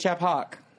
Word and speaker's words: Achaphawk: Achaphawk.